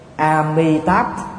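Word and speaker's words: Amitābha: Cả hai đều không chuẩn. A Di Đà là phiên âm Hán Việt của người Trung Quốc, bởi vì người Trung Quốc á, là phát âm cái vần M không được, cho nên chữ nào là M thì họ phát âm thành chữ D. Amitabh thì Trung Quốc là A Di Đà, mà đọc theo là phiên âm Bạch Thoại của Trung Quốc là A Mỹ Thỏa.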